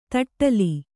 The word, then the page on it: ತಟ್ಟಲಿ: taṭṭali